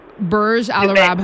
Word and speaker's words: Burj Al Arab: Burj Al Arab Hotel.